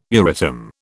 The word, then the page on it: Euratom: British English